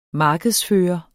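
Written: markedsføre

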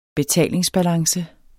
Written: betalingsbalance

